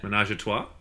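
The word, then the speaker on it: ménage à trois: It also demonstrates that while Pepe Le Pew is a lover, he's not doing a no mas un trois. I completely butchered that. – Menage a trois? – Damn. Yes. Yes, that's what I meant.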